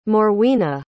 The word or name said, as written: morwenna